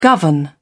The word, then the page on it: govern: British English